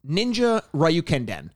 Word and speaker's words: Ninja Ryukenden: The Japanese name was Ninja Ryukenden, and I'd apologize for my pronunciation, except that I fucking nailed it.